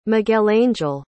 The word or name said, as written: miguel angel